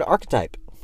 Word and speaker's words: archetype: I forgot to mention last episode that people were shutting me down for the word archetype. Apparently, it's not archetype at all. – Yeah, it's not, because it's spelled A-R-C-H-E-T-Y-P-E.